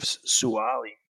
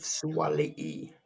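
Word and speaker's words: Suaalii: Well, I'm going to go with Joseph Suwali. – Joseph Suwali. Actually, how it's pronounced.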